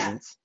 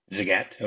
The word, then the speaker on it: Zagat: In 2016, it got a food rating of 4.5 out of 5 by Zagat, I don't know how that's pronounced. And it was Zagat. – Zagat, okay.